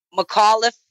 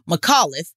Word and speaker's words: McAuliffe: McAuliffe. Okay. McAuliffe.